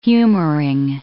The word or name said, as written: humoring